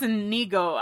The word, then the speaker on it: Inigo: My name is Indigo Montoya. Oh, no, it's Inigo. I always say that wrong. – Inigo, not indigo, yeah.